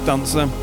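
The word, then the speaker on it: DNCR: This is his collaboration with Dancer, D-N-C-R. I'm assuming that's pronounced Dancer.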